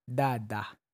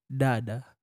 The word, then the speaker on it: Dhaadha: So it's pronounced as Dhaadha. – Dhaadha. – Dhaadha. – Dhaadha. – Dhaadha. – You have no. You have to engage your tongue. Dhaadha. – Dhaadha.